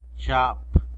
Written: zhap

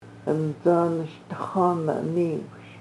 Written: ʔəncə́ n-š/dəx̣ʷan-án̓-iwš

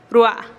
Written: rua'a

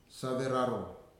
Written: Saveraro

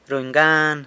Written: runy gan

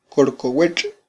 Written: korkowetx

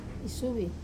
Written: suvi

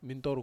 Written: mintoru

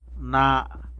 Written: naa

